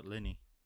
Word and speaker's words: Linney: Lenny. – I think it's Lenny. – How do you pronounce that? – Laura Linney.